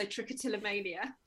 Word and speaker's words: trichotillomania: So you've done, you know, some really interesting articles about eczema, herpeticum, and then also trichotillomania. I can't even pronounce it. – Yeah, it's the longest tongue twister of a word. So trichotillomania.